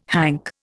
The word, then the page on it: hank: Indian